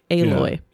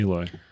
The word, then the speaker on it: Aloy: Phonetic. Aloy. – Yeah, Aloy.